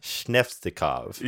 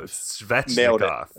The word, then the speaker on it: Svechnikov: Okay, maybe as a send-off, could you teach me how to say the name? – Andre Svechnikov. Shnevektanov. – He literally has a problem whenever there's an S and then another consonant. That's not T.